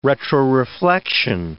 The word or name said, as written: retroreflection